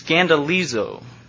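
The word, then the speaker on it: scandalizo (σκανδαλίζω): The Greek word used here is scandalizo. Scandalizo. It's spelled S-K-A-N-D-A-L-I-Z-W. Scandalizo. And it almost sounds familiar, doesn't it? It's a root word for scandal or scandalous.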